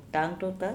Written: TangTotao

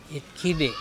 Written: itkhibe

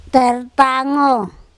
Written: TErtango

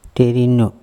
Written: THerino

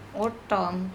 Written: oT TON

